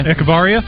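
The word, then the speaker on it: Echavarría: Nico Echevarria, is that how you say it? – Echevarria.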